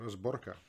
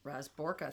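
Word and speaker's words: Razborka (разборка): So Dante tells Papa the Tsar demands Raznorka. – Raznorka. – A reckoning. – Yeah. Razborka. – Razborka. – Razborka. – Razborka, thank you.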